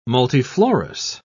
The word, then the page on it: multiflorous: British English